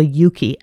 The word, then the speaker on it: Euki: And can you tell us, I see a link here to something called the Yuki app. Can you, I don't yes. – know if I'm pronouncing it correctly. – You are, because the name comes from eucalyptus. And the name of the app is E-U-K-I, and everybody should have it in their phones.